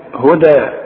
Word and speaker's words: هدى: خلاص على هدى، اذا وقف هي برضو ياء تكتب بالياء، وهكذا كتب القرآن وهكذا يقرأ، هنقراها بالالف هدى، واخد بالك؟